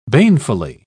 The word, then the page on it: banefully: British English